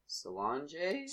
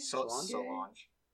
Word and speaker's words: Solange: And the $1 artists are Lil Yachty, Solange? – Solange.